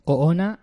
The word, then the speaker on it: Oona: Oona.